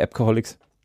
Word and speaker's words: ABC-Coholics: das ABC-Coholics und die Aussprache Appcoholics. So, deswegen. – Wir wollten im Namen das Alphabet haben und wir wollten aber auch ein bisschen nach Alcoholics klingen.